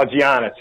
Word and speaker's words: Giannis: Oh, Giannis.